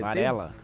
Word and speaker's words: Varela: Foi na padaria, descobri que o doutor, doutor Braulio, Braulio Vazela, ele falou que. – Varela. Tem... Drauzio. – Isso, Braulio Varela.